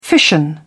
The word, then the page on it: fission: British English